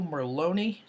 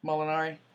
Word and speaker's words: Molinari: speaking of falling off, Francisco Merloni, is that the guy, Ryan? Did I get his name right? Molinari.